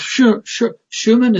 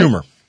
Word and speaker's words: Schumer: Just take an illustration of Schumann. Is that how I'm saying that right? Or Senator Schumann? Is that right? – Schumer.